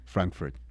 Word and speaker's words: Frankfurt: Frankfurt es que se pronuncia, ¿verdad? Johnson me va a confirmar aquí. – Frankfurt, okay. sí, sí,